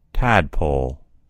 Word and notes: tadpole: American English

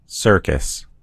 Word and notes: circus: American English

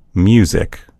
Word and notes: music: American English

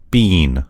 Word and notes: bean: American English

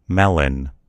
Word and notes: melon: American English

